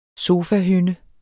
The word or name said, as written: sofahynde